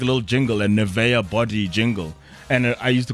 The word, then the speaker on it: Nivea: There are people who know that it's pronounced Nivea.